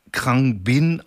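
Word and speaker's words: Khruangbin: Aber jetzt kommt eine neue Band aus Texas, die wird Krang Bin ausgesprochen. Das glaube ich zumindest, so steht es bei Wikipedia, aber ich glaube, die denken sich auch manchmal einfach irgendwelche Dinge aus.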